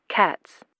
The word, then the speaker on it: Katz: Katz. – And how do you spell that? – K-A-T-Z.